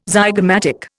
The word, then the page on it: zygomatic: Indian